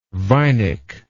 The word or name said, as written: vinic